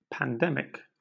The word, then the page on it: pandemic: British English